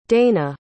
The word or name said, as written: dana